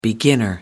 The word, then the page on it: beginner: American English